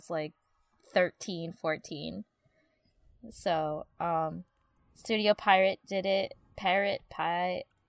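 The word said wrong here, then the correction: Pierrot: Piero. – Piero. It's pronounced Piero.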